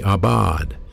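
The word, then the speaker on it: abad (עבד): It's pronounced A-W-B-A-D, abad.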